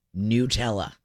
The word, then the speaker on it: Nutella: In the United States, Nutella. It is pronounced Nutella. – Wow. – In the United Kingdom, commonly pronounced Nutella. And in Italy, which they're saying is the original, officially pronounced by Ferrero, Nutella.